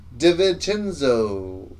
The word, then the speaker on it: DiVincenzo: Dante DiVincenzo.